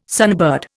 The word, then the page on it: sunbird: Indian